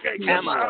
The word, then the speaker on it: Kamala: Kamala.